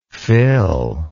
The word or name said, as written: fillable